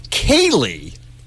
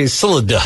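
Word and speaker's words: Ceilidh: I don't care if it's spelled C-E-I-L-I-D-H. I care that she pronounces it Kaylee. – So you want her name to be Silida? – Yes, because that's how it's spelled.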